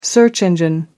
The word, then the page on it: search engine: American English